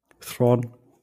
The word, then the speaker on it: Theron: Fand ich, ich habe es eigentlich nur angeguckt, weil ähm, da stand es ist mit Charlie Theron und ähm, oder wie spricht man es richtig aus? Du hast es mich schon äh, Thron. Thron.